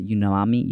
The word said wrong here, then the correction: umami: unami umami umami what yeah.